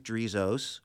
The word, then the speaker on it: Drizos: They can go to my website, which is Steve Drizos, and my last name is spelled Drizos.